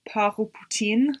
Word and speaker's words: Port au Poutine: How would you say it if it was sort of like French? – Par au poutine. – Ah, there you go.